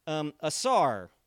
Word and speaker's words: asar: And that word is, let me make sure I spell it correctly, asar. A-S-A-R.